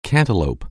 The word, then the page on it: cantaloupe: anglais